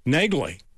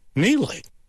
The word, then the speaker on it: Negley: The number one show, I just watched it, Negley, on Prime Video. That's how you pronounce it, okay, if you look it up, Negley. But the lady's name in the show, they call her Neely, Frances Neely.